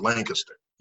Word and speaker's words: Lancaster: And it was always pronounced Lancaster. You know, it was always pronounced in that way with the strong consonants early.